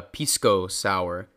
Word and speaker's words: pisco sour: And It's actually called a pisco sour. – Hope I'm pronouncing that correctly. – No, but that's right. That's right.